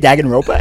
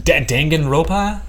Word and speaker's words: Danganronpa: Danganronpa S? – Danganronpa? – Danganronpa.